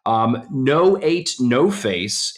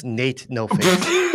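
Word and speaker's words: N8NOFACE: No Eight, No Face. And no, spelled... – It's pronounced... I'm sorry. It's pronounced Nate No Face.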